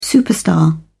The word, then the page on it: superstar: British English